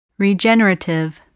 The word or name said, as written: regenerative